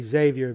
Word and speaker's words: Xavier: And I say Xavier because I looked that up, and that's how I would pronounce it. I don't gargle before I say it, but it's Xavier Cugat. And so far, I'm going to be consistent from now and stick with that.